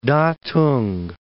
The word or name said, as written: Datong